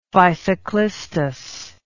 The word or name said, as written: physoclistous